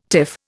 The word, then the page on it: tiff: Indian